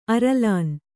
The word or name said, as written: ಅರಲಾನ್